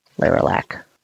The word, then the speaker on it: Leralak: How do you spell that? – L-E-R-A-L-A-K. Lerilac.